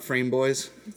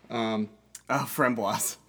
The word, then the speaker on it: framboise: Framboise, so. It's not frame boys. – That just makes me, no, that makes me think of the character in Archer. – Framboise.